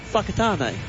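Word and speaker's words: Whakatane: Fakatane.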